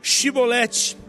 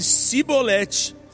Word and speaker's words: Shibolete: Se respondesse que não, diziam: Então diga, Shibolete. E se ele dissesse Sibolete, sem conseguir pronunciar corretamente a palavra, prendiam-no e matavam-no no lugar de passagem do Jordão.